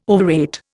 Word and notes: overrate: Indian

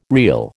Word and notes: reel: American English

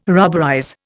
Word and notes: rubberize: Indian

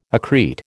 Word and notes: accrete: American English

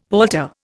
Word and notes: palter: Indian

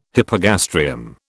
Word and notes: hypogastrium: British English

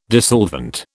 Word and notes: dissolvent: British English

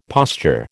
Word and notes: posture: American English

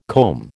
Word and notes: comb: American English

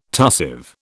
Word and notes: tussive: British English

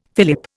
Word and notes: fillip: Indian

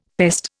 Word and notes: pest: Indian

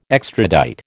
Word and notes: extradite: American English

